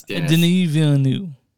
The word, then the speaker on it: Denis Villeneuve: I know it's Denis Villeneuve.